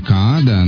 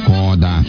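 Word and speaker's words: Kaada: Ну, я всегда раньше произносил его имя Каада, но норвежцы, оказывается, говорят Кода. Вот Кода его зовут, и его второй сольный альбом называется «Мисси Ди» и песенка «Лала».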